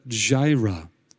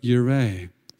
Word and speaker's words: Jireh: Now, you know, the J is not pronounced in the Hebrew, it's the, it's the, the pronunciation for the J is actually the Y. So it would really be Jehovah Yireh.